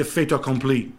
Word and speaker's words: fait accompli: fait accompli.